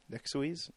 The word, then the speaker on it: Nexuiz: Yeah, the other games are EA's Warp and, I don't know how you pronounce this, Nexuiz? – Nexuiz? – N-E-X-U-I-Z. – N-E-X-U-I-Z? – Yeah. Nexuiz? – Nexuiz. – Nexuiz. – Whatever. – Yeah, that's it.